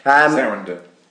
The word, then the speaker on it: Sarandon: And Susan Sarandon as Queen Narissa. Is that how you say it? – Sarandon.